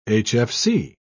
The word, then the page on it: hfc: British English